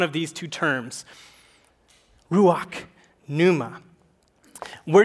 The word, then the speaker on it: pneuma: And in the New Testament, it's a silent P. It's pneuma.